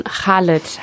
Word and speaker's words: Khaled: Ich habe hier zuerst stehen Didi von Khaled. Habe ich das richtig ausgesprochen? – Das hast du richtig ausgesprochen, genau.